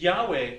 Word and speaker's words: Yahweh: And we come up with Yahweh. That's the nearest scholars think to the truth of how you might pronounce his name.